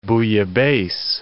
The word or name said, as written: bouillabaisse